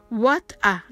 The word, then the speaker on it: what a: Now I know what a fool I've been.Now I know まではいいんですが、 What a fool I've been は早く発音してください。 what a のとこは what a と発音してください。